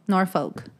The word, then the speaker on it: Norfolk: Do you pronounce the L? – I don't think so. – Norfolk. – I don't think people pronounce the L. Norfolk.